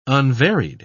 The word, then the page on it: unvaried: British English